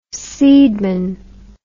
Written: seedman

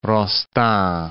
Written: Rostand

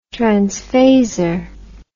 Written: transphasor

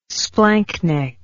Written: splanchnic

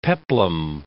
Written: peplum

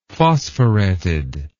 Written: phosphoreted

